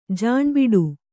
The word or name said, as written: ಜಾಣ್ಬಿಡು